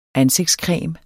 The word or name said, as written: ansigtscreme